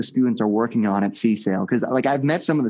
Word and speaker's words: CSAIL: Now, I'm always fascinated by the, it's referred to as, is it CSAIL now? Is that the right way to pronounce the acronym for computer science? – It's CSAIL.